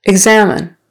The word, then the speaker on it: examine: Examine, examine. It is time to examine the results of our latest sales team. Examine. Second syllable stress.